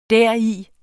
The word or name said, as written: deri